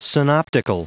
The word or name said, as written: synoptical